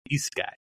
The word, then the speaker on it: isekai: I know that that Mike Stanton mispronounced it. It's supposed to be isekai.